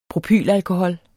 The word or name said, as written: propylalkohol